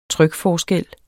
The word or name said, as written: trykforskel